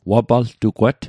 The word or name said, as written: wapaltugwat